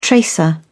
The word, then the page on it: tracer: British English